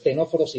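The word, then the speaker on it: ctenóforos: Veíamos que las primeras neuronas aparecían, acordaros, en los dos clados, las dos ramas de ese árbol evolutivo que contienen, cuyo nombre tiene una C que no se pronuncia, ¿vale? Esa es la clave para acordarnos. Los tenóforos y los nidarios, que acordaos, antiguamente estaban unidos en un grupo que se llamaba, que conocíamos como celenterios.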